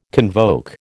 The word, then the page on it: convoke: American English